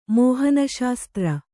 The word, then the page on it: ಮೋಹನಶಾಸ್ತ್ರ: kannada